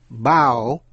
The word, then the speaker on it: bough: Những chữ có O-U-G-H sau đây phát âm như ao hay là o hay là u và gh câm. Thí dụ, bao, B-O-U-G-H, bao là cành cây chính. Dough, D-O-U-G-H, dough là bột nhào làm bánh.